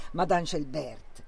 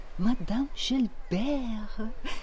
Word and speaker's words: Schelbert: Non alla tedesca, Madame Schelbert. No, con quell'accento francese che se avessi qualche decina di anni in meno mi farebbe sognare centinaia di vite ancora da vivere. Madame Schelbert.